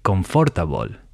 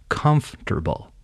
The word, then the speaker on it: comfortable: Most Spanish speakers will read that word and say confortable, when in reality it's pronounced comfortable. So it's actually three syllables, not four, and the emphasis or stress should be on the first syllable comfortable. This happens not just within words, but also within sentences.